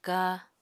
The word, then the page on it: ka: apatani